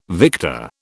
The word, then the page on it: victor: British English